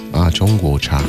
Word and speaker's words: 中国茶: Is it zhongcha? – 中国茶. – Zhongguocha.